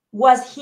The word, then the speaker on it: was: Was se pronuncia con un sonido corto de A.